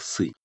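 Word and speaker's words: сы (四): Такое суеверие у них распространилось, потому что иероглиф, обозначающий 4, произносится в китайском практически так же, как слово «смерть». Так как это тоновый язык, то там отличие только в тоне. Судя по всему, и то, и другое произносится как «сы».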